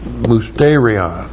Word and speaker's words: Musterion: M-U-S-T long E-R-I-O-N. Musterion.